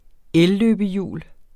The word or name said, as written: elløbehjul